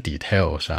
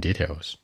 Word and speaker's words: details: Everything in details，注意发音，不是 details 啊，往前靠。 In details，要准备的东西：cream 奶油，chocolate 巧克力，soda 苏打水，stuff like that。